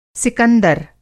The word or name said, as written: सिकंदर